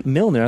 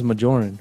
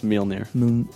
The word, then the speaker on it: Mjolnir: And freaking Mjolnir, I thought it was Majorin. – No, it's Mjolnir. – Is that how you say it? – Yeah, that's how you say it.